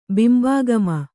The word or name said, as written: ಬಿಂಬಾಗಮ